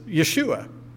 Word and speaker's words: Yeshua: Now, his name, as it would have been pronounced in their language, is Yeshua. They would have called him Yeshua.